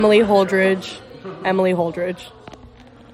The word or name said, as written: Emily Holdridge